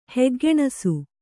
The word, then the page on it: ಹೆಗ್ಗೆಣಸು: kannada